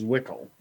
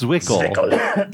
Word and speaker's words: Zwickel: German is Zwickel. – Yeah, in German it's Zwickel. In Czech, it's Zikle. It's Chicle. It's like Chicle, like the ZW is a CH.